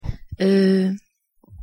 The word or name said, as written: euh